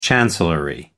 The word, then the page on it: chancellery: American English